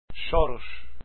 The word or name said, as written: choros